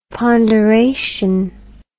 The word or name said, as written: ponderation